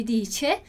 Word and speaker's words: dice: E aí imagina se está perguntando isso para um senhor. Dice qualcosa? E você fala dici qualcosa. Você pode passar por mal educado por ter falado i e não o e.